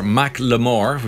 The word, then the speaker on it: Macklemore: Of all of the opening acts on his tour pulled out in protest after the rapper Macklemore, which is how it's correctly pronounced, was axed from the lineup for making pro-Palestinian comments on stage.